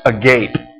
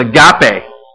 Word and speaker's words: agape: It looks like this, but it's not pronounced agape. Okay? It's agape. Agape love.